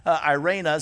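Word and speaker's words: irena (εἰρήνη): And in the New Testament, it's irena is how you pronounce it.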